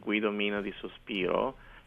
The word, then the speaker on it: Guido Mina di Sospiro: Okay, the website is my name, which is Guido Mina di Sospiro,